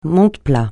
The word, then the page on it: monte-plats: francais